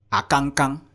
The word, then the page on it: akangkang: Efik